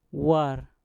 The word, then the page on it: war: mpur